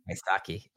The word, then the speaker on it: Wysocki: Am I saying that right? Charles Wysocki? – Wysocki. – Why, why Why is, is hard. Why? – Why Saki? – Why Saki? Wysocki.